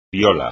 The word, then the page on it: piola: espagnol